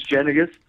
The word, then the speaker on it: Jenniges: My name's Reese Jenniges, spelled like the peanut butter cup.